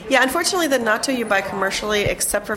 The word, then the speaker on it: natto: So let's start with natto. – Uh-huh. – Is that how you pronounce it? – Natto, yeah.